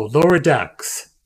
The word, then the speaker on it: Lower Decks: I mispronounced one word and it comes at me. No, Lower Decks. Decks. Lower Decks.